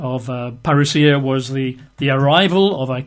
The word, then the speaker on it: parousia: The Greek word for coming is the word parousia. You'd spell that in English, P-A-R-O-U-S-I-A, P-A-R-O-U-S-I-A, parousia, which means arrival, you know, or coming as it's translated very often, in the sort of the papyri of the day.